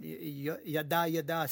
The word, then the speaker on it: jada: From their expression, we would say jada, jada, but it would be pronounced in Norwegian, yada, yada, something anyway.